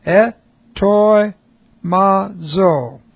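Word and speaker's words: etoi mazo (ἑτοιμάζω): Etoi mazo. This is E-T-O-I-M-A-Z long O.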